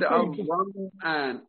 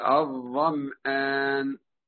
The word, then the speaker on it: الظمآن: أما نشوف مثلا الظمآن، الظمآن هذا النطق صحيح؟ هي الميم هنا ساكنة ولا متحركة؟ – الميم مشددة؟ – الظمآن ساكنة ولا متحركة؟ وأنا قلت الظمآن، الظمآن هذا صح؟ ليس بصحيح، يبقى لازم نسكن الميم.